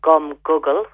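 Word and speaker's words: gum guggul: gum Google, which is spelled gum like chewing gum, and then Google, G-U-G-G-A-L.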